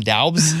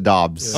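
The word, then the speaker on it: Doubs: We'll call him Daubs. – Daubs. Yeah, I think, it's, I think they pronounce it Dobbs.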